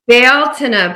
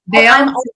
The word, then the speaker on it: Beltane: Say it right. – Beltane, but I just want you to know it's only been recently that I've been pronouncing it correctly, even though I'm Irish. So Beltane. Beltane.